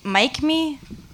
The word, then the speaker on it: Mikme: Okay, the next one up is Mike Me.